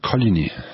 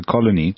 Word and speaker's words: Coligny: Let's use that as a segue to Coligny. Am I pronouncing that correctly? – The people who live there say Coligny. – Okay, so Coligny.